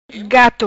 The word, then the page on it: Gato: patua